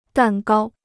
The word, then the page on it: 蛋糕: dàn gāo